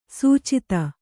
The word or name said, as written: ಸುಚಿತ್ತ